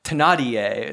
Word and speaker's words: Thénardier: I gave him to a family, Thénardier is the French pronunciation.